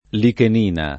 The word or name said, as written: lichenina